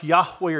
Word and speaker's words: Yahweh: In Hebrew, it's pronounced Yahweh or Jehovah.